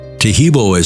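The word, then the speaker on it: Taheebo: Tehebo is spelled T-A-H-E-E-B, like boy, O, then the word T, and then the word club.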